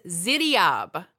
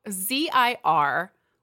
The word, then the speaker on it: Ziryab: I'm going to pronounce it the way that I heard it pronounced. – Okay. – So don't get mad at me for being like on Jeopardy and using an accent to pronounce something that isn't an English word. okay. So this man, his name is Ziryab.